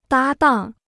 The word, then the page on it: 搭档: dā dàng